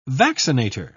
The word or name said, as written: vaccinator